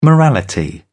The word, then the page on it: morality: British English